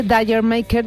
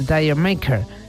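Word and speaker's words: D'yer Mak'er: Esta especie de reggae, cuyo título pronunciado con acento londinense debería sonar como la palabra Jamaica, aunque otra versión afirma que significa Did You Make Her, Dire Maker, Jamaica, Dire Maker, bueno, ahí, ahí.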